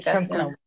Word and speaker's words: SoundCloud: Pero lo único malo que he visto es que solo puedo agregar eh, un audio de SoundCloud, creo que se pronuncia, ¿no? – sí, SoundCloud.